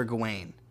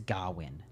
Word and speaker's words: Gawain: Also, I believe it's Gawain, not Gawain.